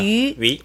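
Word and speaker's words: 위 (鱼): 이 두 개가 중국어로 같은 발음이에요. – 그렇죠. – 위.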